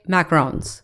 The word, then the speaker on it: macaron: Macarons or macarons? – It's macaron. – Well, there's a macaron, which is like, I think macaroon. The coconut. Macaroon. Oh, macaroon. So it's macaron.